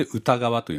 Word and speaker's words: 歌川: に三本川でこれで歌川と読みますが、はい、あの山形県にある小さな川ですねの百の景色歌川百景というタイトルです。